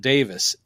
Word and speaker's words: Davies: though he always pronounces it Davis. It's spelled Davies, but he always goes, Greg Davis.